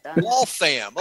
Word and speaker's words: Waltham: from Worthrum, Massachusetts. And from where? – Worthrum? And that in Waltham? How do you say that? – Waltham. – Waltham. Oh, okay. Waltham.